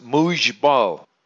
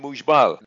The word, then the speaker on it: Muxbal: It's X-E-L-A. How about this one? M-U-X-B-A-L, pronounced Mujbal. I'm telling you, there ain't no Muj in Mujbal. Since when is an X pronounced Uj?